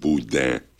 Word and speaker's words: boudin: Because, you know, everybody wants to put an N at the end of boudin. And it's not there. I don't know if you can say it properly, but there's no N. I know there's an N when you read it, but not when you say it.